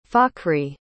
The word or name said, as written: fakhri